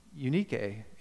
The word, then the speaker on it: Eunice: which first dwelt in your grandmother Lois and your mother Eunice, which, by the way, is pronounced Eunike in the Greek.